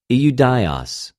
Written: Ἰουδαιος

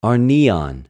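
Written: ἀρνιον

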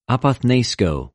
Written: ἀποθνῃσκω

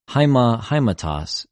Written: αἱμα,αἱματος

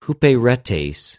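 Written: ὑπηρετης

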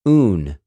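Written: οὐν

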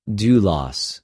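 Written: δουλος

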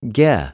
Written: γε

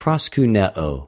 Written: προσκυνεω

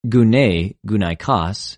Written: γυνη,γυναικος

